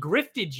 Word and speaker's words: grift: Drift, you spell it like you spell drift, but with a G. G-R-I-F-T. Grift.